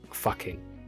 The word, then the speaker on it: Fucking: And the town is actually called Fucking. They pronounce it Fucking, but you know, it's F-U-C-K-I-N-G. It's Fucking, isn't it?